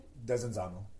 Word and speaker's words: Desenzano: How do you say it again? – Desenzano.